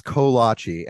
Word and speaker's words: kolache: Oh, I love kolache. – Oh, I argued with my mother-in-law because she's like, it's kolache. And I'm like, okay, Cheryl, I get it. It's a Polish word. It should be pronounced kolache.